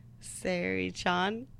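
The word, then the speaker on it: Seiri-chan: And it's pronounced how? – Sari Chan. S-E-I. Sari Chan.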